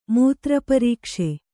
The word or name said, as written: ಮೂತ್ರಪರೀಕ್ಷೆ